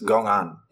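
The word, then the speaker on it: gōan (公案): The word is pronounced gōan in Chinese, and it contains two characters.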